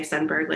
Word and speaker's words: Sundberg: And I just say Sundberg like iceberg, but with a sun is how you spell it.